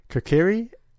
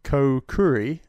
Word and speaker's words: Kokiri: Kokiri, I pronounce it Kokuri, like potpourri.